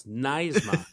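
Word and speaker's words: NYSSMA: NISMA? – New York School Music Association, something like that. New York State School Music. – Oh, NISMA. NISMA. – NY. Is that how it's pronounced? – Yeah, Russ. NISMA.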